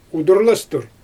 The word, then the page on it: udurlastur: Xyzyl